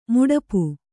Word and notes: ಮಡಿಪು: kannada

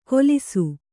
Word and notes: ಕೊಲಿಸು: Kannada

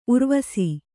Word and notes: ಉರ್ವಿಸು: Kannada

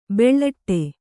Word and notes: ಬುಲೆಟ್ಟು: buleṭṭu

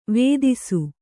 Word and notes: ವೇದಿಸು: vēdisu

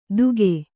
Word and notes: ದುಗೆ: duge

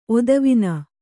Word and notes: ಒತ್ತುವಣ್ಣು: ottuvaṇṇu

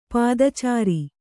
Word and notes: ಪದಚಾರಿ: pada cāri

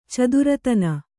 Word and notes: ಚತುರತನ: caturatana

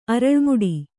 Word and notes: ಅರಳ್ಮುಡಿ: kannada

